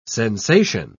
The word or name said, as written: sensation